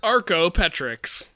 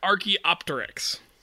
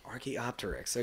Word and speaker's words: Archaeopteryx: Archaeopteryx. Archaeopteryx. Okay. It's not unreasonable for you to realize it's a silent P.